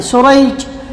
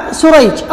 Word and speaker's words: سريج: في النطق، فيقال عقيل ويقال عقيل، أو بالعكس كأن تختلف الأسماء نطقا وتأتلف خطا، وتتفق الآباء خطا ونطقا، كشريح بن النعمان وسريج بن النعمان، الأول بالشين المعجمة والحاء المهملة، وهو تابعي يروي عن النبي عن علي رضي الله عنه، والثاني بالسين المهملة والجيم، وهو من شيوخ البخاري، فهو النوع الذي يقال له المتشابه.